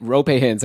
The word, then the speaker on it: Roope Hintz: Because like, you know, you could trade Rupe Hint, Roope Hintz. I guess I should say his name right.